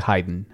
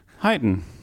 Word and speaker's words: Haydn: ¿Cómo se dice Haydn? Haydn. Haydn. – Haydn. Ahí, ahí aprobamos. – Haydn. Nosotros decimos más una vocal final, Haydn o Haydn.